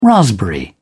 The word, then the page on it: raspberry: British English